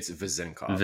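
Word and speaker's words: Vezenkov: Like, I'm most excited about Vezinkov or Cove. I don't know how to say it correctly yet. – It's Vezinkov.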